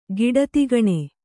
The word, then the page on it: ಕುಡಿತೆಗಣ್ಣು: kuḍitegaṇṇu